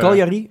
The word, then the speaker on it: Cagliari: Cal eh, Cagliari? Oh, dat is de Italiaanse club. – Italiaanse eh, club. ja, oké. Ja. die Ja, oké. Zo spreek je het dus uit? Cagliari.